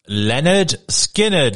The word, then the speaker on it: Lynyrd Skynyrd: That's the second track on their debut album, pronounced Lynyrd Skynyrd. That's how you pronounce it.